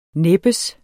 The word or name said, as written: næbbes